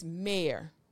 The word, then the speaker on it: Maire: It's not in my notes, but my last name is Mayor, which happens to rhyme with hair, because I am everything about hair.